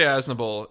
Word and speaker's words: Aznable: It's Osnable. – That's right. – Well, no, we'll see. – Am I pronouncing it Osnable? – They always say Aznable.